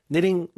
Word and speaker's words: knitting: 우리 그 뜨개질을 knitting이라고 하잖아요. – 그렇죠. – 물론 K는 묵음이지만. – 아하. 네. – knitting, 뭐 뜨개질을 하면 이렇게 촘촘히 짜야 되잖아요.